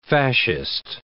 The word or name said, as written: fascist